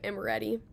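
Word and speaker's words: Emeriti Park: The next place that is a favorite at OU is, please tell me if I say it wrong, but Amoretti Park. – That's right.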